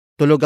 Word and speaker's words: tulog: Tulog is spelled as T-U-L-O-G. It is an adjective that means asleep. Note the stress at the end, so it is not confused with tulog.